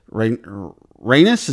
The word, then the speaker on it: Rhaenys: who is Rhaenys.